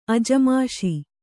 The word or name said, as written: ಅಜಮಾಷಿ